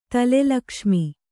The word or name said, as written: ತಲೆಲಕ್ಷ್ಮಿ